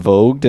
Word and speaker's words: Vogt: Vogt? – Yeah, from 2022. – No G. Well, there is a G, but there is a G, but you can't pronounce it.